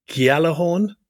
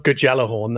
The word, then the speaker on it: Gjallarhorn: I think it's called the Gjallarhorn. I think the J is pronounced as a Y. It's not Gjallarhorn, that's for sure.